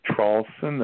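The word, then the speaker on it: Traulsen: My full name is Hugh, H-U-G-H, last name Trawlson. That's spelled T like Tom, R-A-U-L.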